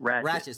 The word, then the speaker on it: Ratched: And my wife is currently watching, I don't know if I'm mispronouncing this, it's called Ratched or Ratchet. – Ratchet.